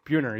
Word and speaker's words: Buhner: Buhner, yes.